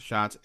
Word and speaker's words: Schatz: If you want to follow Aaron on Twitter, he is at FOASCHATZ, but it's pronounced Schatz, Aaron Schatz.